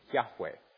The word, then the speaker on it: Yahweh: So now there's different ways of pronouncing it, and it seems from the people who speak Hebrew naturally, normally, who know how these things work, that's how the word should be pronounced. Yahweh.